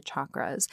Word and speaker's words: chakra: Now, I will say right off the bat, I do pronounce them chakras, like chak or chaturanga. That's my understanding of that is the phonetic way to pronounce them instead of like chakra, because other Sanskrit terms that have the CH, like chaturanga, have the ch.